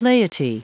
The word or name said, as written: laity